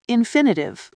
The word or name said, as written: infinitive